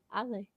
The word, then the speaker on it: Ollie: Like open your mouth with ah, and then you're eating a bag of Lay chips. Lay. Ollie.